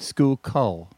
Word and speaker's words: Schuylkill: How do you pronounce S-K-U? I feel like I'm doing the National Spelling Bee. – I know it. – It's like the Schuylkill River. Like Schuylkill.